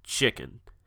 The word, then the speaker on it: Xuiqen: And it's not spelt like you would think. It's actually spelt X-U-I-Q-E-N. But he assures me that it is pronounced chicken.